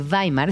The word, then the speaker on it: Weimar: Fíjate que eh, se titula República de Weimar, está bien, pronunciado.